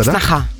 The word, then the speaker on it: сноха: То есть это сноха. Произносится, сноха.